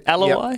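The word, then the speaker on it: Aloiai: Josh Aloy, is that how you say his name? Aloy? – Yep.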